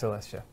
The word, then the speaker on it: Coelestia: Arcana Coelestia, or some people pronounce it Celestia, like Arcana me. Celestia. I pronounce Celestia.